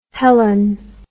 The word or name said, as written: hellen